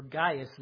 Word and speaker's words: Gaius: John writes to Gaius, or Gaius would be the right way to say it.